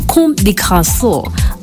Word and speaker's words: Comme des Garçons: The first one is about the Japanese fashion brand Comme des Garçons.